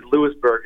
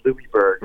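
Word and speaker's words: Louisbourg: I got told by the locals that it is Louisburg and not Louisburg.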